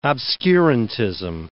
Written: obscurantism

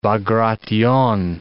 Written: Bagration

